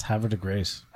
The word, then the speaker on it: Havre de Grace: That's not how you say it. We have... What? – It's a Scobar. – It's Havre de Grace.